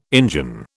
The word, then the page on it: Injun: British English